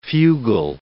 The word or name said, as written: fugal